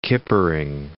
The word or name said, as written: kippering